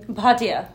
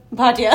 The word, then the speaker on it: Bhatia: Bhatia. – Okay. Akanksha Bhatia.